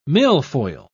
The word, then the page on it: milfoil: British English